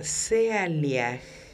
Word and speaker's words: Sealiah: Flor, tu ángel se llama Sealiaj y se escribe S-E-A-L-I-A-H.